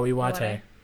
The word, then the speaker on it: Iwate: Iwate is his Geodude. I probably just butchered that, but whatever. – Iwata? Oh, Iwate.